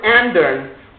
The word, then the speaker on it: ändern: But that word ändern rhymes with the word rändern.